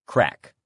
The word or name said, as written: crack